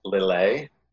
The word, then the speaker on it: Lillet: I think what makes this one a little different is you get the it's pronounced Lillet, L-I-L-L-E-T, Lillet Blanc. – Okay.